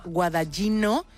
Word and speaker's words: Guadagnino: Fíjate, esta película está dirigida, espero decir bien el nombre porque tú esto lo vas a pillar muy rápido, por el director italiano Luca Guadagnino. – Bueno, a ver, no es como lo hubiese pronunciado vale. Yo porque soy de allí, pero lo has salvado.